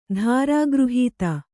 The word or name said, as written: ಧಾರಾಗೃಹೀತ